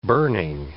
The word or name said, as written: burning